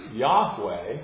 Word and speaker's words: Yahweh: Four letters are YHWH, which we pronounce as Yahweh, but it is a name that the Old Covenant people of God so revered that they would not pronounce it.